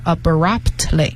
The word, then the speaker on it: abruptly: 这里面 P 和 T 都是要发音的，只是说它比较轻，所以就是听的是 abruptly。